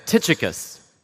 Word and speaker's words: Tychicus: And by the way, did you notice Zach did say Tychicus, right? So it's not like Tychicus or something like that, which I've heard that name pronounced all kinds of different ways, but it doesn't really look like Tychicus, but it is.